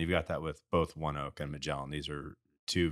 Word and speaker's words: ONEOK: As I was listening to this morning on CNBC driving in, ONEOK, that's how they pronounced it, ONEOK bought Magellan.